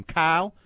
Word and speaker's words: Cyhl: I mean Quarles' Kyle. – Name. – Kyle. – Kyle Quarles, defensive back. C-Y-H-L is his first name.